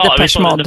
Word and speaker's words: Depeche Mode: Ja, Depeche Mode.